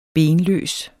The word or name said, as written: benløs